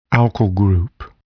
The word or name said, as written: alkyl group